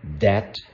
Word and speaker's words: debt: So remember the pronunciation here. Those of you learning English as a second or foreign language, remember debt, net, and set. Debt is a net that Satan sets for our souls.